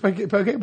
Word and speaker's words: poke: It's poke when you want to sound fancy on air. Oh, okay. Poke bowl. – Does it rhyme with croquet? – It does.